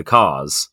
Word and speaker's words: Kerr: Actually, like their descendants, the Carrs, whose surname is spelt Kerr,